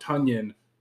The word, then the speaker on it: Tonyan: Yeah, it's Tonyan like Funyan. – Tonyan like Funyan. – I thought it was weird, though, because in the press conference, they were like, you say it Robert Tonyan, not Robert Tonyan, but you say Tonyan like Funyan.